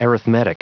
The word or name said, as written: arithmetic